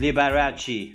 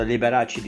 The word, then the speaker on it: Liberace: Dedim ki neden Liberace demiyor da Liberace diyor. Sonra araştırmaya başladım. Annesi Polonya Konyalıymış. Babası da aslında İtalyan olduğu için galiba telaffuz olarak Liberace diye okunuyor.